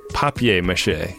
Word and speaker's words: papier-mâché: It's papier-mâché. – Papier-mâché. – This is a P-A-P-I-E-R, which is pronounced papier.